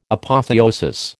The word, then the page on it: Apotheosis: American English